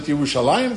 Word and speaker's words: Yerushalayim: It's spelled in the Aramaic without the Yud. But in the Hebrew, we pronounce it Yerushalayim.